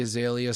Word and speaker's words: Azalea: But one of the trending videos was, how do you pronounce her name? It's, I know Iggy, but is it Azalea? – Yep, Iggy Azalea. – Iggy Azalea. – And not Iglesias.